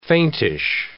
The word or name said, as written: faintish